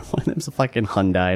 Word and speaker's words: Hyundai: No, well, Hyundai rhymes with hung guy, so yeah.